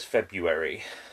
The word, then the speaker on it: February: Why is it spelt February, but everybody says February? – Silent R. – The R is silent.